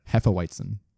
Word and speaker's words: Hefeweizen: It is a German Hefeweizen. Did I say that right? – Hefeweizen.